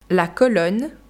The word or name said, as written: la colonne